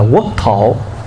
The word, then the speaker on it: quốc thổ: Quốc độ là mình đọc là quốc độ, chứ đúng chữ chúng ta phải đọc là quốc thổ.